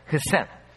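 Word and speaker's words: хесед (חסד): Вот точно так в Ветхом Завете есть слово, которое называется хесед. Произносится как хесед.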